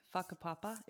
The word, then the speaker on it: Whakapapa: Whakapapa, am I pronouncing that correctly? – Yeah, perfect.